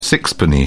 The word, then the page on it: sixpenny: British English